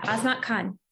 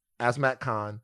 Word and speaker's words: Azmat Khan: Azmat Khan. – Azmat Khan.